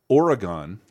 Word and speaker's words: Oregon: Pig is utterly, and also like there's not a ton of movies shot in this area of Oregon because I pronounce it like the game.